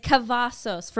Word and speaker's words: Cavazos: it's Cavazos for anyone who's confused on how to pronounce it.